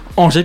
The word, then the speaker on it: Angers: Angers plutôt, je prononce bien, s'il te plaît Justin.